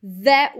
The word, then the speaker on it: that: That was, então esse that também não precisa pronunciar o T, fica tudo junto,